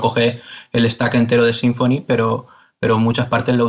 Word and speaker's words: Symfony: Y entonces, claro, eh, lo normal que se utiliza en PHP ahora, sin, lo más estándar es Symfony, eh, Symfony mm. como lo llamamos nosotros, ¿no? Como se pronuncia en inglés,